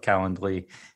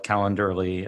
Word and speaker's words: Calendly: I feel like I now need to just wholeheartedly endorse my usage of Calendly and even pronounce it right and not call it Calendarly as I have to do.